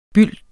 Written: byld

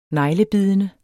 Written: neglebidende

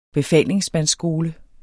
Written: befalingsmandsskole

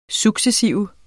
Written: successive

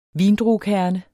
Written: vindruekerne

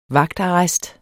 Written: vagtarrest

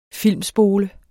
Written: filmspole

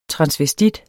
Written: transvestit

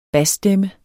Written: BASSTEMMER

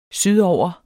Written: sydover

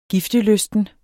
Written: giftelysten

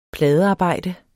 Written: pladearbejde

